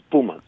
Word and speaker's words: Pumas: I've been saying Pumas because I've been hearing the sports announcers say Pumas, but you'd say Pumas? – Yes, the pronunciation in Spanish is Pumas.